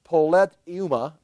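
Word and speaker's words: politeuma: The word right there is a word, it's poletuma, I'll spell it for you, P-O in the Greek, P-O-L-I-T, and you can hear politics right there, P-O-L-I-T, then E-U-M-A.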